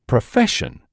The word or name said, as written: profession